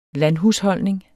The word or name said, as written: landhusholdning